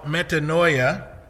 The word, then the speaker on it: metanoia: Well, there's a Greek word that you can look at called metanoia. That's how I pronounce it. The Greeks probably don't pronounce it that way, but that's how I do.